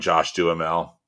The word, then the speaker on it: Josh Duhamel: But I ended up picking When in Rome, the romantic comedy starring Kristen Bell and Josh Duhamel, if I'm saying his name right.